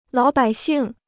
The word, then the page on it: 老百姓: lǎo bǎi xìng